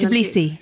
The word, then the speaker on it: Tbilisi: Is it Tisby? Is that how you say it? Is that correct? – Yes. Tbilisi. Yes.